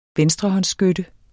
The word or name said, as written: venstrehåndsskytte